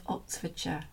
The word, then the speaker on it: Oxfordshire: As long as you don't pronounce it in the American way, the Oxfordshire and Gloucestershire. It has to be sh, doesn't it? – Yes.